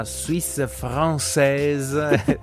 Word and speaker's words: Suisse Française: Io sono Davide Gagliardi e di nuovo il turno della Suisse Française. Ritroviamo Florence Grivel dell'RTS. Ma perché ridi? Non ho detto bene Suisse Française? – Ma è perfettissimo, è talmente carino.